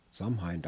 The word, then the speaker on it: Samhain: Ausgesprochen Samhain. Samhain, da gab es auch mal eine Heavy-Metal-Band, die so sich nannte.